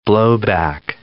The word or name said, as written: blowback